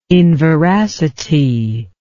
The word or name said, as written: inveracity